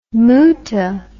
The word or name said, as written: moota